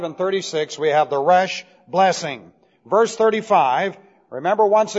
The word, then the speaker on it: Resh: This is pronounced Resh.